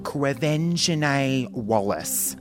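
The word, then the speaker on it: Quvenzhané Wallis: It is Quevenzane Wellis.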